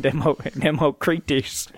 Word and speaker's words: Democritus: But so you have this classic debate of Plato versus Democritus and pretty much Democrates. – I was gonna say, yeah, that's probably how it's pronounced. – Democrates. Yeah, I always say those Roman names Democritus. – Wrong. Democritus.